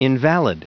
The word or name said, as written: invalid